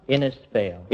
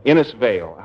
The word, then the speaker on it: Innisfail: Innisfail. – Innisfail. How do you spell it? – I-N-N-I-S-F-A-I-L.